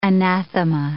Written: anathema

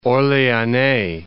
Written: Orleanais

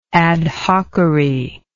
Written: adhockery

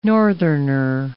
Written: northerner

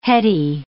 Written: heady

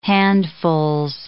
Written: handfuls

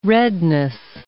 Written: redness

